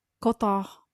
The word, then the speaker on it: Cotard: It's called Cotard.